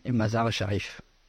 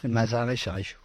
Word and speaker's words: Mazar-e-Sharif: Oui, Mazar-e-Sharif. – Mazar-e-Sharif. – Mazar-e-Sharif, ouais.